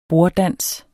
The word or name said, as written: borddans